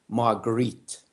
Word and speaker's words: Marguerite: I mispronounced her name, about a Margaret de Valois. And he just gave me the oddest look, and he said, Not now, I don't. and later on in the class, he just looked at me and he corrected my mispronunciation. He said, Marguerite.